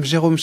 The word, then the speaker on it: Jérôme: For an American, I would say Jerome, right? – Yes. – What do your English-speaking friends call you? They call you Jerome? – Yeah, like this. Yeah, Jerome, yeah.